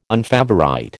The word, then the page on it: unfavourite: American English